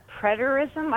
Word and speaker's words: preterism: And we all met last night, and we were talking about preterism. I hope I'm saying it right. – Yeah.